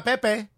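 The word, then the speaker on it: Pépé: But uh, if Nicola Pepe because that's how we pronounce his name now.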